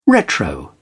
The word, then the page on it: retro: British English